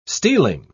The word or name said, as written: stealing